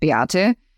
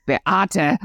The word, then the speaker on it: Beate: You may even hear still the accent, and my name, I think, gives it away a little bit, Beate. If you say it like my mother, it'll be like you're yelling at me. – Yeah. – It'll be Beate.